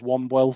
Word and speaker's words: Wombwell: It's spelled like Wombwell. I think the correct pronunciation is Womwell.